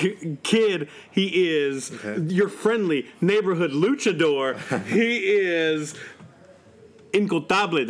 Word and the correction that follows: Intocable: Did I say that right? – Intocable. – Intocable.